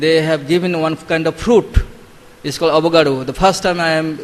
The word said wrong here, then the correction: avocado: Avocado. Okay. So then, it's pronounced differently.